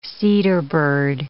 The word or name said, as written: cedarbird